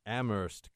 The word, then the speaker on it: Amherst: And you say, well, if you went to Amherst College, you don't pronounce the H.